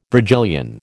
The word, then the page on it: Virgilian: American English